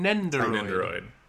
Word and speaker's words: Nendoroid: I think that the Nintendroids how do you say it again? – Nendoroid. – Nendoroid, okay.